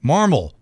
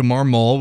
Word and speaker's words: Marmol: It's two syllables. Marmol, like marble. Marmol. And for some reason, people want to change it to Marmol, which is incorrect.